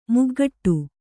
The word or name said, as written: ಮೊಗಗೊಡು